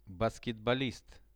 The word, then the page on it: basketbolist: Russian